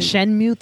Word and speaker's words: Shenmue: I know with, like, the PC gaming show, they showed a couple, like, was it Shumo 3? I can't pronounce that name. – Shenmue 3? – Shenmue 3 will be a P...